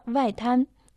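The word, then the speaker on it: Вайтан (外滩): Лучшее место, где можно полюбоваться архитектурными особенностями города Шанхай, это, конечно, набережная Вайтан. Вай произносится в четвертом доне, в переводе «внешняя часть», «наружная сторона». Тан – первый дон, переводится как «набережная».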